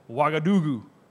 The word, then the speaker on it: Ouagadougou: It's called Ouagadougou.